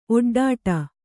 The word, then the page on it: ಒಡ್ಡೊಡೆ: oḍḍoḍe